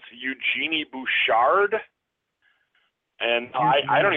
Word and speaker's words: Eugenie: I just know how to say her name because it's French, Eugenie.